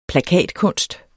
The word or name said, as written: plakatkunst